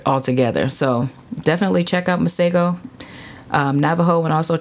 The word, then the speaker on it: Masego: And then Masego.